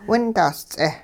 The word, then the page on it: windahs-ts'eh: hupa